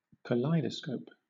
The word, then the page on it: kaleidoscope: British English